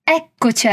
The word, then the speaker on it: eccoci: Un'altra volta, osserva bene la pronuncia delle doppie C in eccoci e la doppia R in arrivati. Ah, eccoci arrivati.